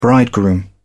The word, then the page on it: bridegroom: British English